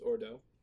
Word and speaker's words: Ordo: The German occultist Theodore Russ later accuses him of publishing the Secrets of his own occult order, the order of order. No, it's Ordo. I said it right the first time.